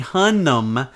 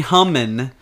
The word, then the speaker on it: Hunnam: It, it, so when you find Jax, then you find Charlie Hunnam, not Charlie Hummin.